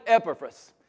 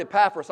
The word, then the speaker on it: Epaphras: And there was a guy named Epaphras. And even though I've heard Epaphras all my life, the correct pronunciation is Epaphras. So I'm going to put the right focus on the right syllable, as Brother Ken says.